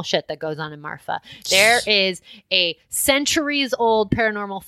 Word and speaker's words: Marfa: If y'all have more information on Marfa, any of you are like locals or Texas locals. – Is it with an F? Am I saying it right? – Yeah. – Okay. – M-A-R-F-A.